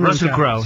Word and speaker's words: Russell Crowe: Yo quiero decir, Russell Crowe se llama, ¿cómo se pronuncia? – Russell Crowe, sí.